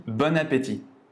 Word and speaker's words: bon appétit: Il faut faire la liaison à la fin du mot bon jusqu'au début du mot appétit. On dit bon appétit.